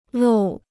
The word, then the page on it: 漏: lòu